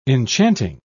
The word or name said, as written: enchanting